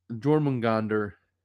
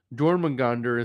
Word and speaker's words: Jormungandr: Jormungandr in Norse mythology.